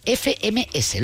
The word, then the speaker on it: FMS: la FMS. ¿Lo he dicho bien? – Sí, lo has dicho perfectamente, la mejor, o sea, la competición que más importancia tiene en el mundo del rap.